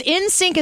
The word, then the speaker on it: NSYNC: The other random thought I had this morning about NSYNC was that... – NSYNC, not that NSYNC. – Bye. – It's NSYNC as in I-N, but you're saying it like it's just the letter.